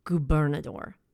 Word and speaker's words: Gobernador: It's called Tacos Gobernador. And it's spelled G-O-B-E-R-N-A-D-O-R, Gobernador.